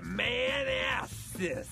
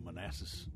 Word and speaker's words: Manassas: Why can't he just say Manassas? Why does he have to place strange emphasis on different syllables of the name of the town?